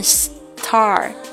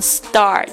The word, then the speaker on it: star: 我们不是读成 star，而是读成 star。